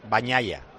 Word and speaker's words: Bagnaia: Porque digo Bagnaia, y es Bagnaia.